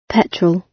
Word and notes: petrol: British English

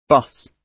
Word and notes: bus: British English

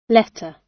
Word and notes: letter: British English

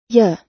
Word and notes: you: American English

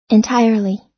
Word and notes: entirely: American English

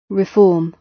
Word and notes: reform: British English

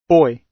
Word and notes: boy: British English

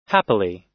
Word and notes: happily: American English

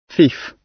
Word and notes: thief: British English

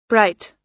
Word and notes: bright: American English

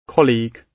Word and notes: colleague: British English

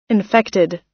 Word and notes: infected: American English

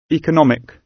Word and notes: economic: British English

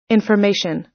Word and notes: information: American English

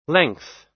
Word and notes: length: American English

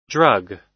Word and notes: drug: American English

